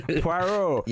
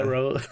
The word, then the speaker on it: Poirot: How do you say his name? Poirot. Yeah, yeah. Poirot.